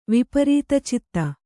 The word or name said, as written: ವಿಪರೀತಚಿತ್ತ